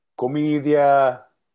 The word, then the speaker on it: Commedia: Commedia.